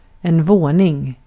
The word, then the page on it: apartment: American English; [ʌ.ˈpɑɹt.mɛnt]